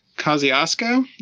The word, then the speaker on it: Kosciuszko: It was Mount Kosciuszko, is that right? – That's correct. That is correct.